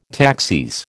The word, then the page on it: taxis: American English